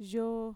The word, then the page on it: yoo: Mixtec